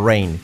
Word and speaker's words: rain: Car, C-A-R. Pronunciación, car. Repetid, car, car, car. Ese sonido. Lluvia. ¿Cómo se dice lluvia en inglés? Escrito rain, rain. Pronunciación correcta, rain, rain. Fijaos que cuando es al principio de sílaba, la R, y antes de la vocal, hace un efecto como una U. Rua, rua, rua.